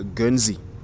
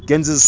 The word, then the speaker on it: Guernsey: The Channel Island of uh, Guernsey.